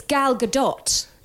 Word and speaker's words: Gadot: oh, by the way, it's Gal Gadot.